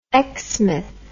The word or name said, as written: exmouth